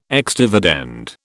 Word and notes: ex dividend: British English

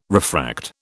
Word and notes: refract: British English

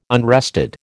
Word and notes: unrested: American English

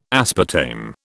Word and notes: aspartame: British English

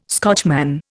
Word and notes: Scotchman: Indian

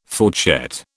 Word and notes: fourchette: British English

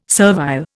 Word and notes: servile: Indian

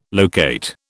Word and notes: locate: British English

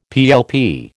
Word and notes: PLP: American English

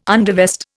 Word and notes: undervest: Indian

